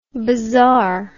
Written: bazar

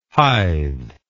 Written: hythe

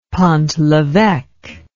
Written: pont leveque

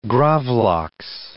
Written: gravlax

